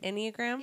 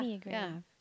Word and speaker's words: Enneagram: It's not Instagram, guys. It's right. Enneagram. Am I saying it right? Enneagram? – Yeah. – Enneagram. – Yeah. Okay. I mean, a lot of people think it's an I, and then they just type it in, and then Instagram comes up.